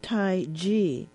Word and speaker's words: Tai Chi: But just to give you a bit more information, Tai Chi is actually pronounced with a G. Tai Chi was originally formed as a training process for developing one's skills in the martial arts.